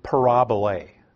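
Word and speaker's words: parabole (παραβολή): When you look at the word parable in the Bible, if you had a Greek text sitting next to you, it would be the word parabole. They put the accent a little differently and put a long A at the end, but it's the same word, parable.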